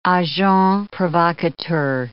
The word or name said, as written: agents provocateurs